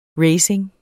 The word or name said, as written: racing